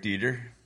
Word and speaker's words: Dieter: But Dieter is spelled like as if you went on a diet, and you're a dieter.